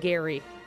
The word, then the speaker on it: Gerry: I'm going to correct you just because I've done some research on this and you pronounce it Gary even though it looks like Jerry.